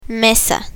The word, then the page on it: mesa: spanish